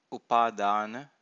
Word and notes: upadana: Pali

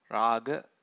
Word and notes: raga: Pali

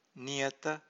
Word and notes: niyata: Pali

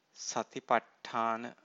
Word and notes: satipattana: Pali

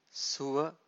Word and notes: suva: Pali

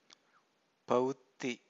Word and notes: pavutthi: Pali

